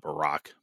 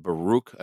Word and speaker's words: Baruch: So you have Tobit, there's Judith, there's Baruch, not Barak, but Baruch, I believe is how it's pronounced.